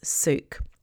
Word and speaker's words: souk: I later find out that the name of the market is, or the Arabic word for a market is souk.